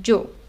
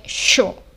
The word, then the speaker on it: yo: A pronúncia dessa letra também vai variar de país para país, porque a palavra yo, que significa eu, é, na maioria dos países é pronunciado desse jeito, jo, como se fosse um d, d, como se fosse um D com J pra gente no português, assim, imaginando. Mas na Argentina é pronunciado como se fosse um sh. Então é show, não jo.